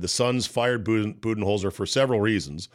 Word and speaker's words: Budenholzer: Mike Budenholzer.